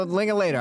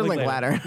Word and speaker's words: Linklater: Second episode in a row, we said Richard Linklater because I said something about like that rhyme with Linklater. And I go, yeah. I was like, no, Linklater. I go, Richard Linklater. – Linklater. So imagine him mm-hmm.